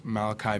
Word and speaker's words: Malaki: It's Malachi Music, MALAKI, and then Music.